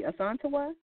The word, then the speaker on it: Asantawaa: Asantawaa, is that correct? – That's correct. Yes. – And awesome. And so, Asantawaa.